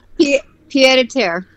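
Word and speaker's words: pied-à-terre: I don't know how to pronounce it, but it's P-I-E-D-A-T-E-R-R-E. Petit terre? – Petit terre. – There you go.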